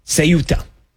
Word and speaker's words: Ceuta: Okay, I got to say it right. I've been saying Quetta. I've been saying Ceuta. Ceuta. I apologize.